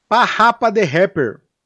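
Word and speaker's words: PaRappa the Rapper: o segundo game é o Parrapa the Rapper.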